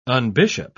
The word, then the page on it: unbishop: British English